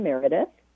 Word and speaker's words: Meredith: Okay, so my YouTube channel is called Energy Medicine with Ellen Meredith.